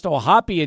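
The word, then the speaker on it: O'Hoppe: Because I didn't even know Logan Ohapi's last name was pronounced Ohapi until I heard it in a ball game, and we've been calling him Ohap forever.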